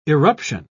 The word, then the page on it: irruption: British English